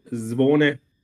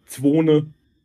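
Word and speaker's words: Zvone: Ähm, ursprünglich, eigentlich heißt es richtig Zwone. Die Deutschen sagen manchmal Zwone.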